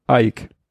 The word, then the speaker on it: Eich: Brandon Ike spricht man auf Englisch wohl Ike.